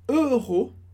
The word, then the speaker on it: euro: Então, o valor dessa compra é 16 euros, e aqui é importante também a pronúncia, euro. A gente tem duas sílabas. A primeira é e, o som e, como em monsieur, e, euro, euro.